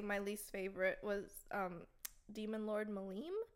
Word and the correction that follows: Milim: Oh, Milim.